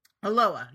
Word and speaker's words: Aloha: Yeah, no, it's spelled Aloha, but you just don't pronounce the H for some reason.